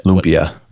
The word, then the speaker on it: Lumpia: My wife, being Filipino, would correct your pronunciation there. – What Lumpia.